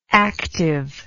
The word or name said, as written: activeness